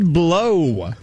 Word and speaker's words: Blough: David Blow. – How do you spell that? – B-L-O-U-G-H.